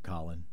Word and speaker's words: Colin: Just under the banner of pronunciation police, he pronounces it Colin. – Colin, okay. Colin right. Kaepernick.